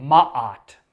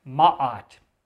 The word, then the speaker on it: Ma'at: And so, as you read these Egyptian instructional texts, there's a concept at the center of it called Ma'at. Ma'at.